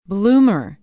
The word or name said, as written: bloomer